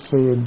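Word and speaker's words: Syed: My name is Sayed Duddin.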